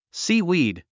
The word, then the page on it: seaweed: síːwìːd; スィーウィード